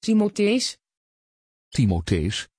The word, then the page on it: timotheus: Dutch